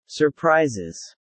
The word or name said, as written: surprises